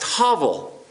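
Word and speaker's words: Havel (הבל): Abel in Hebrew is pronounced Havel.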